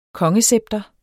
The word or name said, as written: kongescepter